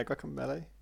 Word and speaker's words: Guacamelee: Yeah, Guacamelee.